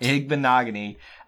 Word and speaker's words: Igbinoghene: Igbinoghene is what I used to say. – Yeah, that was incorrect. – Igbinoghene.